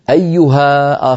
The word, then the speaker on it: أيها: الألف حذفت لالتقاء الساكنين مثلا، مثلا نقول قال تعالى وتوبوا إلى الله جميعا أيها المؤمنون، أيها المؤمنون، أيها آخرها ألف، لكن لما نقول أيها المؤمنون نصل أيها بكلمة المؤمنون فإن ألفها تسقط للتخلص من التقاء الساكنين.